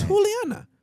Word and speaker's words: Juliana: It's Juliana. – Right. – Or it's Juli. Mm-hmm. The J is a H in Spanish. – Mm-hmm. – That's the way you pronounce her name.